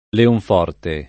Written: Leonforte

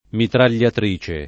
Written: mitragliatrice

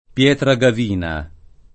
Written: Pietragavina

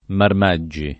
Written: Marmaggi

